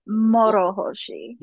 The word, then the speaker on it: Morohoshi: Morohoshi, just roll the R a little bit. – Morohoshi.